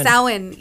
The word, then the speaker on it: Samhain: Samhain, yes.